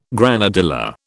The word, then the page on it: granadilla: British English